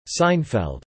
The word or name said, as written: Seinfeld